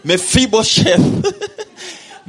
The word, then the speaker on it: Mephibosheth: Mephibosheth. Oh my goodness. Mephibosheth.